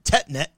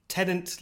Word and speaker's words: Tenet: It's like before we started recording, it's like, is it Tetnet or Tenant? – I think I've come to the, it's Tenant, like David Tennant, only without the N at the end, so it's David Tenet.